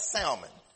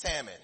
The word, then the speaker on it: salmon: It's not salmon. It's salmon.